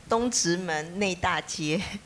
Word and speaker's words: Dongzhimen Neidajie: Bueno, sí, lo conocí, ahí dice básicamente este es el barrio de Yanjun, en que vos lo vas a poder pronunciar mejor porque el barrio Dongzhimen Neidajie.